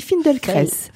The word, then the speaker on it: Feldenkrais: On dit Feldenkrais. – Feldenkrais. C'est difficile à prononcer, puis il y a beaucoup de prononciations, parce qu'en fait c'est une prononciation russe. Alors si vous prononcez à la française, c'est très bien aussi.